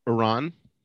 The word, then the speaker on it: Iran: Iran.